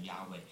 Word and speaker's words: Yahweh: 所以所有你按照希伯来文的读音的这个规则，应该读成 yahweh，但是犹太人呢从来不这样子的读，好，那么他们只敢读成 adonai。